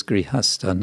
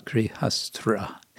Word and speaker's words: Grihastha: And the word is Grihastha, not Grihastra.